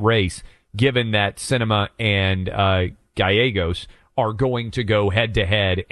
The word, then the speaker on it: Gallegos: but Ruben Gallegos, and I might be pronouncing his last Gallegos.